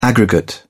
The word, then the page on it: aggregate: British English